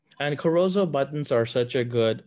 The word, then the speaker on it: Corozo: Yeah, Corozo. – I always mess it up. I say Caruso, but I know it's Corozo. So we've got some really good Corozo buttons that look beautiful, and it gives a nice contrast.